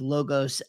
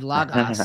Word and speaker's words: Logos: Logos is the Erasmian from Erasmus pronunciation, and it's a pedagogical tool, meaning it's the most helpful way to teach people to, you know, Greek as a language, Koine Greek. But the way it was actually pronounced in Koine times, it almost certainly was not short O's.